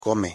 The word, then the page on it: come: Spanish